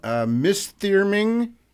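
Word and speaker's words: Misþyrming: Mistyrming, I think I'm pronouncing that correctly.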